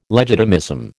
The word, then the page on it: legitimism: American English